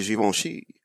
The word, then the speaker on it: Givenchy: it's pronounced Givenchy. I think he said that, and then she was like, she actually corrected him, and was like, it's actually Givenchy.